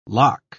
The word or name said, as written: lakh